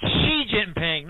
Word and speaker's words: Xi Jinping: For the love of God, it's Xi Jinping, not G-Z-Z-G-G-G-Chi-Ki-Chi. It's she. English pronoun she.